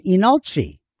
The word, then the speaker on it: Leenalchi: So we've been hearing from Inalchi, which is spelled L-E-E-N-A-L-C-H-I, and you just don't pronounce the L, and then you're pretty much dead on.